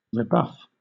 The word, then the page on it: rebuff: British English